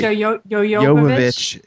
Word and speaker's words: Jovovich: I'm saying her name incorrectly. – Jovovich.